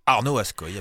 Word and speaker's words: Arnaud Ascoy: Et non, ce n'était pas Brel qui chantait, mais bien Arnaud Ascoy. C'est comme ça qu'il faut prononcer? – Arnaud Ascoy, absolument.